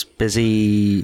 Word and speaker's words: Bisi: That's Busy from Bizimoto. – Oh. – B-I-S-I.